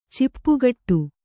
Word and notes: ಚಿಪ್ಪುಗಟ್ಟು: Kannada

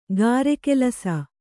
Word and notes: ಗಾರೆಕೆಲಸ: gāre kelasa

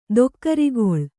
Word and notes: ದೊಕ್ಕರಿಗೊಳ್: dokkarigoḷ; kannada

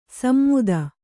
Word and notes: ಸಮ್ಮುದ: smmuda; kannada

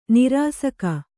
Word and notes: ನಿರಾಸಕ: nirāsaka

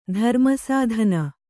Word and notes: ಧರ್ಮಸಾಧನ: Kannada

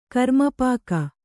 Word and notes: ಕರಮಾಪಕ: Kannada

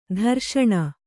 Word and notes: ದುರೇಷಣೆ: Kannada

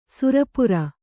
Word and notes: ಸುರಪುರ: sura pura; kannada

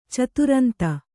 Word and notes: ಚತುರಂತ: caturanta

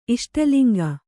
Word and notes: ಇಷ್ಟಲಿಂಗ: iṣṭaliŋga

